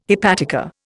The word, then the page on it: hepatica: Indian